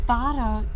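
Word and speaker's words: Varah: V A R A H Y O U F O O L S. It's pronounced Fara, you fools.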